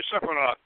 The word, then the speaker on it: Sephirot: Am I pronouncing Sephirot? Is that Ashkenazic or? – Oh, my rabbi, Laban Ben Clifford, says you can pronounce it any way you want. – All right. Sephirot.